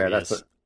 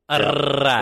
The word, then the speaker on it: Arias: Whose name did they butcher or mispronounce? – Arias. – Oh yeah, okay. Arias. – Arise. – There was another one too. I can't remember, but they definitely just mailed in Arise.